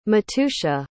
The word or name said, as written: matuxa